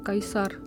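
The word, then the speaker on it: Caesar: Takže eh, v západní Evropě se, se, se vyslovuje kikero, kajsar, takhle.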